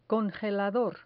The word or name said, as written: congelador